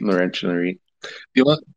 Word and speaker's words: Laurentian: The pronunciation is Laurentian.